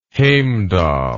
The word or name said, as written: Heimdall